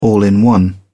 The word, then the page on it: all in one: British English